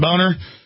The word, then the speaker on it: Boehner: Speaker of the House, John Boner. I mean, John does pronounce Boner. He tells us to say Boner.